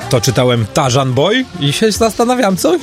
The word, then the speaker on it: Tarzan: Tarzan, że niby RZ, ale nie czyta się jako Ży, tylko Rzy.